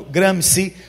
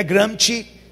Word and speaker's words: Gramsci: E o principal personagem que realmente diagnosticou esse problema foi um italiano chamado Antonio Gramsci. A pronúncia em italiano é Gramsci, mas vou falar Gramsci porque é em português.